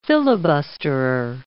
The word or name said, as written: filibusterer